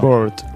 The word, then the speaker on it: bored: No sé, mai sé com es diu, avorrit, com es pronuncia això. – Bored. – Bored. – Si ho bird. Poses a... Hi ha pàgina web que t'ho diuen. – Però sembla ocell, no? Si dic bird. – Bird. Bored.